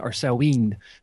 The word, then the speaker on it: Samhain: You were spot on with Samhain. Depends which Gaelic kingdom you're speaking in.